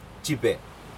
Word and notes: jipe: Aneityum

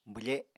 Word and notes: bwfe: apatani

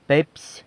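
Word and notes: pepsy: San Lucas Quiaviní Zapotec

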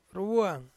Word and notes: ruwuang: Ansus

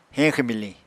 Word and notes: heñxw-miliñ: Apatani

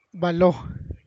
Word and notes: baloó: Santiago Matatlán Zapotec

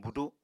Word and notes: budu: Apatani

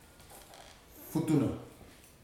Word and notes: futuna: futuna aniwa

